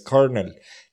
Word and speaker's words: colonel: Por ejemplo, cómo se pronuncia colonel, que es coronel, es colonel. No tiene por ningún lado la R, pero suena una R.